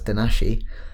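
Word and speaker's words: Donají: This species is offered to the Princess Donahi, which is pronounced softly in Zapotec as Danashi, who, according to the Zapotec legend, was a beautiful granddaughter of King Cosihueza, the last great king of the Zapotec civilization.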